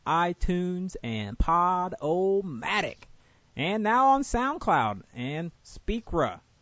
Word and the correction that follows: Spreaker: Did I say it right? – Spreaker. Where do you get the A at the end? – I don't know, man.